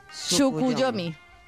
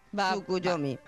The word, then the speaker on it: Tsukuyomi: ¿Tsukuyomi? – Tsukuyomi. – Wow. – Digo yo que se dice así, tal cual. – Tsukuyomi.